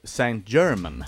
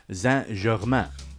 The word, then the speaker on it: Saint Germain: På svenska så har vi ju alltid Saint German. – Ja, just det. – Men ska man vara lite fransk klingande och korrekt uttal så heter han faktiskt Saint Germain.